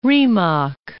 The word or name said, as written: Rimac